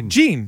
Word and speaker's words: Jean: Was it Jean? – How do you say? Gene. – I think it was Gene.